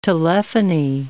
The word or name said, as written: telephony